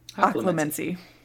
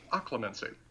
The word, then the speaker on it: Occlumency: Occlumency. – Occlumency.